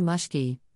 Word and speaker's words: mushki: Sometimes he'll say, "wanna mushki," rhymes with push, which means a scratch on the head.